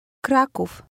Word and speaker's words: Kraków: Kraków, do Krakowa.